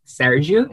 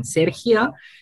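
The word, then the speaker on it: Sérgio: My name is pronounced Sergio in Portuguese and Sergio in Castellano in Spanish.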